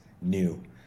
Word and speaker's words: NIU: And the easiest way to pronounce it in English or in any kind of romantic language is NIU. But I have, you know, up in Northern Europe, they call it NIU-E.